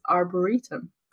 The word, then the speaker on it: arboretum: Looked it up on YouTube how to pronounce this word, and in British English it is arboretum.